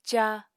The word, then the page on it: caa: apatani